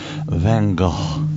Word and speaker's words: Van Gogh: Vincent Van Gogh.